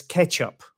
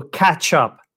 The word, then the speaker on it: catch-up: And you have to be careful not to pronounce it as ketchup, which is something else, right? Ketchup is something that you would use with your chips or French fries. So to catch up, a sound, not a.